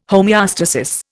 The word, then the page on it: homeostasis: Indian